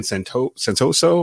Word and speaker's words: Santoso: Sly missed another field goal, and they had already traded for Ryan Santoso, am I pronouncing that right? – Yes.